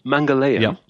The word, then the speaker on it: Mangalyaan: Okay, it's called Mangalyaan.